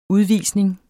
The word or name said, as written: udvisning